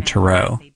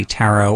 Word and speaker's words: tarot: She's an author and a tarot card expert, although you'll hear her use the European pronunciation tarot. Now, I had actually never had a tarot or tarot reading before.